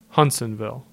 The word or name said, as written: Hutsonville